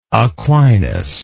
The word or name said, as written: Aquinas